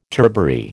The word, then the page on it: turbary: American English